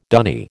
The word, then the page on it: dunny: American English